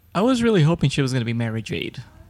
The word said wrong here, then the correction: Mara Jade: I thought it was Mara Jade. – Mara Jade. Well, Mara, you know. I'm Mexican. I pronounce things differently.